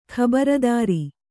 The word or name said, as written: ಖಬರದಾರಿ